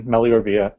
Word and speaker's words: Meliora Via: You're getting it right. It's Latin. Meliora Via means better way.